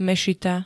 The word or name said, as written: mešita